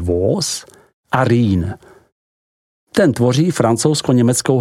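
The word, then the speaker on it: Vosges: Za to vděčí pohoří Vogéze, vyslovujeme Vóz, chránící region ze západu, na jehož úpatích a svazích nalezneme všudy přítomnou vinnou révu.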